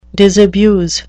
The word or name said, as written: disabuse